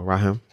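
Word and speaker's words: Raahim: So it's called, I'm not, his name is spelled R-A-A and then H-I-M. – Okay. – I think it's two I's and then him. So Raheem.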